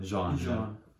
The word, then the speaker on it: Jean: No. Jean. Like John, but with a J. – Yeah. – With the little French on it.